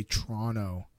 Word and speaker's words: Toronto: If you want to say it like you're native, do not pronounce the final T. And okay. you can even drop the first O. So you say Toronto. T-R-O-N-O. Toronto. Kind of.